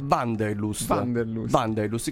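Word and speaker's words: Wanderlust: Sì, non è semplice, perché è un termine tedesco. – Me lo rispieghi, come si pronuncia? – Vanderlust, che significa ossessione per il viaggio.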